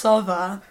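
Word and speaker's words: Sowa: It's actually Polish, it's supposed to be pronounced Sova, because we have no V in the Polish alphabet.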